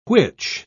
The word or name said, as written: which